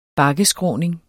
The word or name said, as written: bakkeskråning